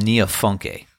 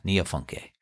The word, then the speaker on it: Niafunké: Niafunké. I think that's how you pronounce it. Neofunk, Niafunké. And uh, it's named after the town where Ali Farka Touré lived on the Niger River in Mali.